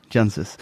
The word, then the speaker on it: Jancsics: It's pronounced, it's spelled J-A-N-C-S-I-C-S. – Yeah. Yes. – But C's are silent. – Okay. – So it's just pronounced J-A-N-S-I-S. – Okay. Jansis. – Jansis. – Jansis.